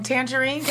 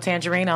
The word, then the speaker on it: Tanjareen: If you know how to spell my name, it's not spelled like the fruit. It's spelled TanjarEen. So that's Official Tandrine on Facebook,